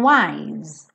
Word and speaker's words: wise: Don't know if I'm being wise. Wise, não pronuncio é.